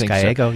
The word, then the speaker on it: Gallego: But now a state lawmaker, Assistant House Minority Leader Ruben Gallego, is that the right pronunciation? – I believe it's Gallego, so.